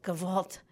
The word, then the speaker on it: Gewalt: Gewalt is Did I say that correctly? – You said that correctly, yes.